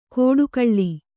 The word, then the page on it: ಕೋಡುಗಳ್ಳಿ: kōḍugaḷḷi